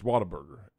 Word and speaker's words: Whataburger: Sneeze Snackers, it's when you start doctoring up food and you go, oh, we got a Whataburger burrito. We got Indian food. – It's Whataburger, not Whataburger. – It's Whataburger. It's Whataburger.